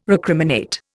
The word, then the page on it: recriminate: Indian